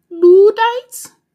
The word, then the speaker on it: Luddites: Luddites? – Yes, Luddites. It's spelled L-U-D-D-I-T-E-S.